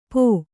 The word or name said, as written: ಪೋ